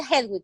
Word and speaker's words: Hedwig: O sea, porque se pronuncia Hedwig, ¿no? – Sí.